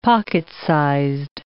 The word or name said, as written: pocket-sized